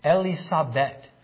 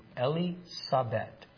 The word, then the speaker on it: Elisabeth: E-L-I-L-E in Hebrew. Sabet. Elisabeth is the proper pronunciation of Elizabeth in Hebrew. Elisabeth.